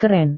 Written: keren

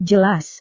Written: jelas

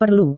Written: perlu

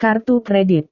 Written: kartu kredit

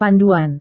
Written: panduan

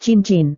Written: cincin

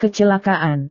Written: kecelakaan

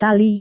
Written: tali

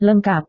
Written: lengkap

